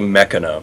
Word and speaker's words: Machina: Is it Machina or Machina? – It's actually Mechana. – Mechana. Damn it! – None of us were right. – We were putting the emphasis on the wrong syllable.